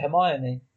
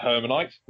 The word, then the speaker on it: Hermione: Because I've always pronounced it Hermione. – I'm not a strong reader. And I was like, Hermanite? No, just never pronounce it.